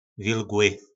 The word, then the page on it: vilgly: po našimu